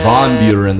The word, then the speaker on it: Van Buren: It's actually Von Buren. That's the correct pronunciation.